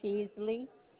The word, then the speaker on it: Peasley: Charlotte Peasley.